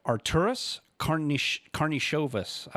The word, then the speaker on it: Arturas Karnišovas: They reassigned Paxson, and they hired, I'm going to try it, Arturas Karnišovas.